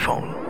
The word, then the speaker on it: phaune: It's spelled P-H-A-U-N-E. But it's pronounced phone.